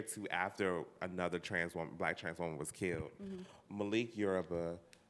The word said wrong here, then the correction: Malik Yoba: Did you say Malik Yoruba? – Is that not his name? – Malik Yoba. Yoba.